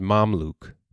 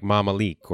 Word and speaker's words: Mamluk: The Arabic pronunciation for a single one is Mamluk. Plural is like Mamalik or Mamalik.